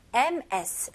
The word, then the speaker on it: Ms: MS, 스펠링은 MS이지만 발음은 Z 발음에 더 가까워요.